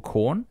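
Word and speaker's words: Quorn: but it's called corn. It's made of corn. It's spelt with a Q.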